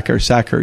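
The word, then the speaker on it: Sachar: Howard Morley Sacher? Sacher? – Sacher, yeah.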